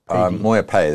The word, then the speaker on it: Moya Pay: It's pronounced Moya Paid, is it? Is that correct? – Moya Pay, there's no D on the end. – Oh, the D's gone.